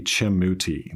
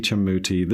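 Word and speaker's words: Chemuti: I think I'm pronouncing it right, State v. Chemmuti. That's C-H-E-M-U-T-I, State v. Chemmuti. This deals with our state law on law enforcement recordings.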